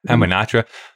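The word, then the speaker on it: Hamunaptra: Hamanatra. They have a P in there, though. Are you saying that's a silent P?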